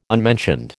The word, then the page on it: unmentioned: American English